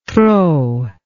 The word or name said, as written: trop